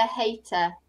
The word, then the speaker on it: hater: From the hater. – From the hater. – Yeah, I need to hear the H. Hater.